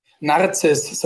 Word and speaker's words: Narziss: Narziss, so wie die Narzisse, die Blume.